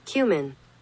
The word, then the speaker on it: cumin: I'm just telling you how it's supposed to be pronounced. Do you want to hear the British pronunciation of it? – Yeah, cumin. – Cumin. – Google is wrong. – It's pretty much the same. – Listen, I just said slower. If I say it fast, of course it's going to sound like that.